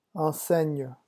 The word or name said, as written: enseigne